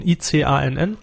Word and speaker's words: ICANN: Äh, Maximilian hat noch geschrieben, neue Top-Level-Domains von der, wie wird das ausgesprochen, ICANN?